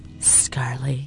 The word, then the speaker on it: mince and tatties: Well, you've got like you could have mince and scurly. – You've got to say a bit mince and scurly. Scarly, yeah. – Roll your R a bit there, yes.